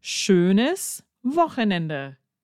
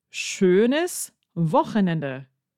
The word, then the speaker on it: schönes Wochenende: And I could very easily then turn around and teach him schönes Wochenende. Schönes Wochenende.